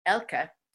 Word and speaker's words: Elke: My name is spelled E-L-K-E, even though you're pronouncing it correctly, Elka.